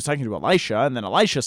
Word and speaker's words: Elisha: Um, Elisha, we often called him Elisha, but his name is actually pronounced Elisha.